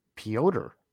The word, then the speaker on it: Piotr: And we need to apologize to Kachetkov because we were pronouncing it Peter, but it's actually Piotr. – Piotr. – It's Piotr. Yeah.